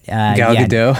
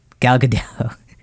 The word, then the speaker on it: Gal Gadot: But Gal Gadot? – Yeah. Gal Gadot. It's not Gadot.